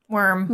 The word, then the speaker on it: wyrm: Yeah, the W-Y-R-M. – I have always pronounced it worm, but I heard people pronounce it as Wyrm, and I'm like, that doesn't seem right, but sure. – It's still worm.